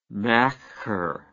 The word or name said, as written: macher